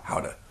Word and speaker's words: Gouda: some people, depending on what region of Netherlands you're in, it's either hard or soft G, but he says Gouda when you ask him to pronounce it in Dutch.